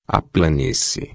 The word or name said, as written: planície